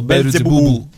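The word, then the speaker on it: Beelzebub: La serie è Belzebub o Bel- Belzebub, o dipende come lo. In Giappone mi dicevi la pronunciano Belzebubu, perché giustamente la B da sola non si può dire in giapponese.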